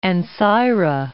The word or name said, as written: Ancyra